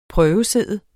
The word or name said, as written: prøvesidde